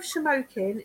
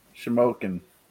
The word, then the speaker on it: Shamokin: And you pronounce that with no problem. – What? – Shamokin. – Shamokin. – Right. Bravo. Spoken like you lived in PA your whole life.